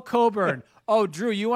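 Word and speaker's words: Cockburn: Kofi Coburn, which I'm surprised it's pronounced Coburn, but after you see the guy, if he told you his name is pronounced a certain way, you would go, yes, sir, whatever you want, sir.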